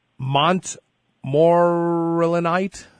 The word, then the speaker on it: montmorillonite: Natural multi-mineral dietary supplement, montmorillonite.